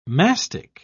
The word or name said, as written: mastic